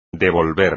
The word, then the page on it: devolver: espagnol